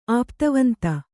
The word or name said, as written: ಆಪ್ತವಂತ